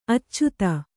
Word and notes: ಆಚದು: Kannada

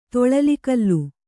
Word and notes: ತೊಳಲಿಕಲ್ಲು: toḷali kallu

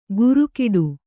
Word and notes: ಗುರುಕಿಡು: gurukiḍu

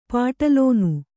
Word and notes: ಪಠಿಲ್ಲನೆ: paṭhillana; Kannada

